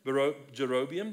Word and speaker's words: Jeroboam: Then there is a king, Jeroboam. I believe that's the right pronunciation. Jeroboam, the king.